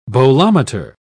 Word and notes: bolometer: British English

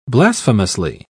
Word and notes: blasphemously: British English

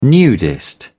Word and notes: nudist: British English

